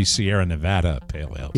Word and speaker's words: Sierra Nevada: Correct pronunciation, that should be Sierra Nevada Pale Ale.